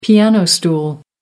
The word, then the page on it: piano stool: American English